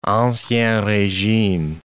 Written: ancien regime

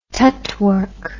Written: tutwork

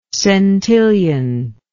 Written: centillionth

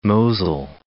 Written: Mosel

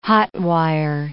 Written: hot-wire

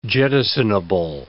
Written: jettisonable

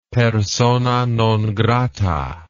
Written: persona non grata

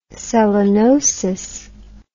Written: selenosis

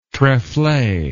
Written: trefle

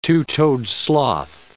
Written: two-toed sloth

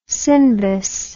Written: Sindbis

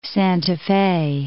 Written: Santa Fe

